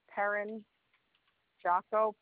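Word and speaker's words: Perrin-Giaco: C-A-R-R-I-E, last name hyphenated, it's Perrin Jocko, P-E-R-R-I-N hyphen Jocko, G-I-A-C-O.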